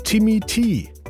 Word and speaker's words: Timmy T: Es sind nämlich zwei harte Ds drin. Timmy T.